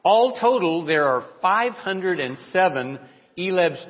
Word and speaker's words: ILEB: And it goes by those initials, in which the name in Spanish is ILEB, pronounced ELEB.